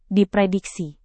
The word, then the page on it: diprediksi: Indonesian